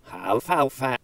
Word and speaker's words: alfalfa: So, I believe it is pronounced backwards, aflafla. And forwards, it sounds like alfalfa.